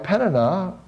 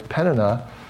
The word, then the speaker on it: Peninnah: Now, what we also see that's contributing to her hurting is that this second wife, Peninnah, I think I pronounced it wrong, Peninnah, it's Peninnah, she's mistreating her.